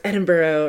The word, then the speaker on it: Edinburgh: Now, Greyfriars Graveyard, and I know my American accent's going to butcher the hell out of it, Edinburgh. I know, it's like, I have friends like always correct me, no, it's Edinburgh, or I don't know, I'm so sorry.